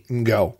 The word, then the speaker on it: Ngo: And I have to say this, I'm very sensitive to trying to pronounce people's name correctly, and Tony's last name is spelled N-G-O, and I looked up online, and the last name N-G-O, the first two letters N-G are pronounced like the end of the English word wrong, so you have that N-G, and so I believe the correct pronunciation is Tony Ngo.